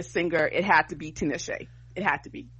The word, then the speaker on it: Tinashe: Okay, it does look like, according to this, is pronunciation Tinashe. So you're right. My bad. Tinashe.